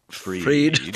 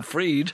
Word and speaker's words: Fried: Freed. Thank you. Yep. Even though it's spelled fried. – Yep. – Okay, Sam Bankman Freed.